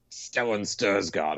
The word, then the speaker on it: Stellan Skarsgård: Best supporting actor, Stellan Skarsgård, or is that how you say his name? – Yeah.